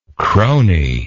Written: crony

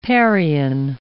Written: parian

